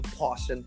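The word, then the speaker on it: Pawssion Project: Hindi yun mispronunciation yung Potion Project.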